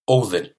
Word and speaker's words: Odin: Odin.